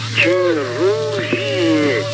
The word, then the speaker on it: Chirurgi: Lo pronuncio en español. Chirrugie. – Sí, bueno, en español. – Eso es. – Chirrugie. – Eso. – Bueno, que lo correcto sería chirrugie.